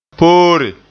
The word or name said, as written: poore